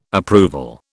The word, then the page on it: approval: British English